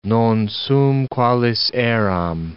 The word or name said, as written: non sum qualis eram